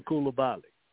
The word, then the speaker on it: Coulibaly: Yes, thank Bilal Coulibaly.